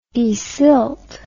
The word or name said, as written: desilt